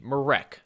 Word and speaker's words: Marek: And Quincy Guerrier provides something to Syracuse that Merrick Dolezaj does not. Excuse me, Marek. I've been saying it wrong this whole time.